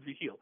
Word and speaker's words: Vigil: At the time that I was doing my research, I called Mrs. Sherry Vigil, or Vigil. It's the Spanish pronunciation is Vigil.